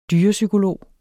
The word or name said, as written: dyrepsykolog